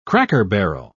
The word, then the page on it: cracker-barrel: British English